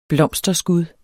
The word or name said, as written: blomsterskud